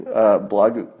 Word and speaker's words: Blagojevich: Blagojevich. I think the proper pronunciation is I think I nailed that, but you know what I'm talking about.